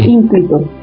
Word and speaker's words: ínclito: Mamá, hija, si yo conozco esa palabra, pero con el acento en la primera. – Sí. – Ínclito.